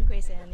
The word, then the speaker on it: Grace An: Grace Ann, yes.